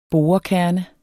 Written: borekerne

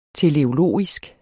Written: TELEOLOGISK

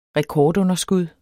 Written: rekordunderskud

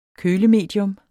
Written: kølemedium

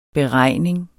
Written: beregning

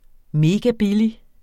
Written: megabillig

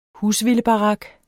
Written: husvildebarak

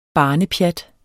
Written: barnepjat

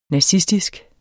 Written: nazistisk

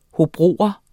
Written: hobrogenser

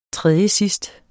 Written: tredjesidst

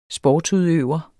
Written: sportsudøver

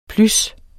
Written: plys

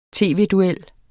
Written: tv-duel

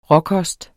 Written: råkost

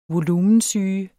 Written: volumensyge

